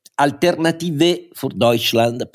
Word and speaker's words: Alternative für Deutschland: cioè Alternative für Deutschland, perché il nostro ascoltatore ci becca dicendo eh no, voi pronunciate all'inglese, non fate sentire la E finale.